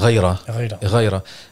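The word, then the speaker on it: غيرة: So the word is, from what I've read, the correct pronunciation is ghayra.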